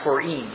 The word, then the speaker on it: Purim: it's pronounced Purim, is that right? Purim is the correct pronunciation, which actually has kind of a little more of a Hebrew pronunciation to it.